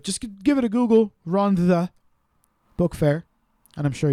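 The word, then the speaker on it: Rhondda: No, there's no D. The double D is pronounced as a TH. – Double D. – Rhonda.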